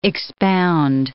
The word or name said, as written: expound